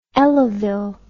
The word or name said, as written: elavil